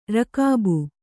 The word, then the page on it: ರಕಾಬು: rakābu